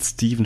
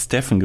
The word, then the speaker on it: Stephen: Und der Typ von Adidas hat seinen Vornamen falsch ausgesprochen, hat Steven statt Stephen gesagt.